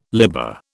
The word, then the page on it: libber: British English